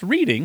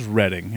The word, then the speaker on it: Reading: Which I always had pronounced Reading because I just didn't know any better, but it is Reading.